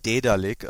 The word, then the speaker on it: Daedalic: Es wird, ich habe auch jetzt mal nachgeschaut, es wird wirklich Daedalic ausgesprochen.